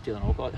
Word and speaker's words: Dylan Alcott: Dylan Alcott.